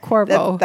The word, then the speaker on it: Quavo: Oh, in Migos. Quavo. – Quavo. – Quavo. – That is correct.